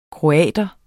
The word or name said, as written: kroat